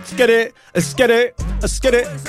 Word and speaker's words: Esskeetit: It's Skitt It. – It's really spelled like that? – Yeah, E-S-S-K-E-E-T-I-T.